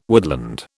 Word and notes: woodland: British English

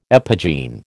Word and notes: epigene: American English

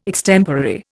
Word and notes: extemporary: Indian

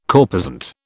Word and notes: corposant: British English

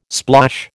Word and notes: splosh: American English

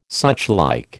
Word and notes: suchlike: American English

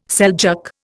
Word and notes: Seljuk: Indian

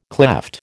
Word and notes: klepht: American English

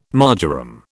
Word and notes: marjoram: British English

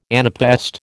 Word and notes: anapaest: American English

